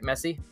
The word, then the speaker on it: Messi: We pronounced that right? Messi. – Yeah.